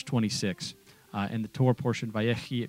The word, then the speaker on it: Vayechi: Here in studio with Pastor Nick Plummer, and we are discussing the Torah portion, Vayechi, which here in Florida we say Vayechi, which is the correct Hebrew pronunciation, of course.